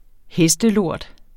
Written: hestelort

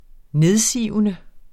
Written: nedsivende

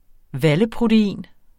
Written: valleprotein

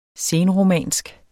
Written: senromansk

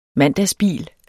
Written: mandagsbil

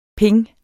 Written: ping

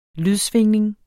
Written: lydsvingning

